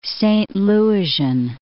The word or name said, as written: Saint Louisian